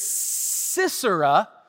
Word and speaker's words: Sisera: It's important that you say it like that because Sisera, his name has some meaning too.